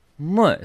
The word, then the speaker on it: muy: And then it's moy. So it's moy. – Moy. – You got the accent on the more. – Although if I'm in Britain, I just say mooey because nobody can pronounce it. – Yeah, I know. But that's why I thought the right pronunciation phonetically, moy. – Moy. – Yeah. – Mm. – That's very impressive, isn't it? – Because they said mooey. And I always go, no, it's not muy.